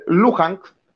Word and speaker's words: Luhansk: Luhansk, eh, se pronuncia, muy parecido a la, ciudad china donde salió el coronavirus de todo esto,